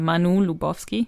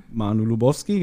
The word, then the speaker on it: Manu Lubowski: Was, was mir halt aufgefallen ist, ähm, der, der Sprecher von Klößchen, das ist ja der, ich kann den Namen nicht aussprechen, Manu, Manu? – Ja, Manu Lubowski, ja. Manu Lubowski, genau.